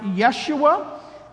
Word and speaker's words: Yeshua: The name would be more traditionally pronounced Yeshua, and that means Yahweh saves.